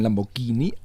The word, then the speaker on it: Lamborghini: Lamborghini sagt man auch immer ganz gerne, aber es ist das Italienische. – Ich hätte das gesagt. – Ja, man spricht man ja mit G aus, das Italienische. Ah, da kann man ja noch drauf kommen. Es gibt ja durchaus Leute, die Italienisch sprechen, Lamborghini.